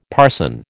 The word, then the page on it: parson: American English